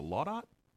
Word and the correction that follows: Laudate: Laudate. Yeah, Laudate.